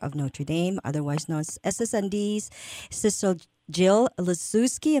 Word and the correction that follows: Leszewski: Am I pronouncing that correctly, Sister Jill? Close. Thank you for trying. It's Sister Jill Leszewski. – Leszewski. Okay.